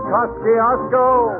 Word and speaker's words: Kosciuszko: Where parents name their children Roscoe. – Because it rhymes with Kosciuszko.